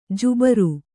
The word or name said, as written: ಜುಬ್ಬರ